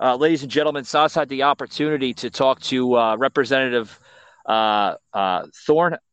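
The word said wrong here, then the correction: Ramthun: Is that how you pronounce his name? – Timothy Ramthorn.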